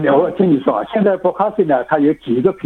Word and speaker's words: Bokashi: Bokashi Bokashi。 – 对，因为这个就是日文的发音了，因为是日本人发明的，所以它一发音的也是日本文。